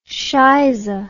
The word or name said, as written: scheize